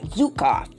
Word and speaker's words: Zukav: I hope I'm pronouncing that right. Zukav. Z-U-K-A-V.